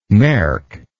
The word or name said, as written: merk